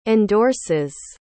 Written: endorses

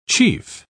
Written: chief

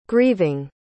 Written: grieving

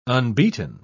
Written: unbeaten

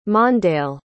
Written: mondale

